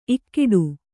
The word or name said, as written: ಇಕ್ಕೋಡು